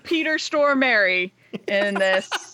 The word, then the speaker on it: Stormare: Peter Stormare.